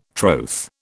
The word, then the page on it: troth: British English